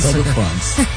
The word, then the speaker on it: Stade de France: ¿Cómo se pronuncia? – Stade de France.